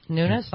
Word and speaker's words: Nunes: How do you say his name? Nunes? – Nunes, I think. Yeah. – It's Nunes, yeah.